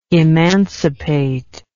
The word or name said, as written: emancipist